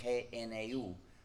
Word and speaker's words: GNU: Se escribe GNU y se pronuncia normalmente en inglés GNU, pero en castellano se puede pronunciar GNU como si comenzara por ñ, lo que es el nombre del mismo animal.